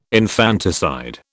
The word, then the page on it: infanticide: British English